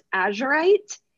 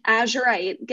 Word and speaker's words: azurite: So this is called, I believe it's pronounced azurite, A Z U R I T E, and right under the name it says get laser focused, which wait, that's actually crazy.